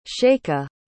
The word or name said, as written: chayka